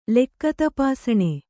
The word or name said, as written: ಲೆಕ್ಕತಪಾಸಣೆ